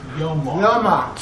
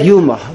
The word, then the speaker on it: Yoma: So the Yeshiva Sha'olam, they say Yuma. Okay, so 99% all the Bnei, they say Yuma. – I proved them wrong. – Okay, that's obviously wrong. It's not Yuma, it's Yoma.